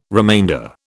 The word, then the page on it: remainder: British English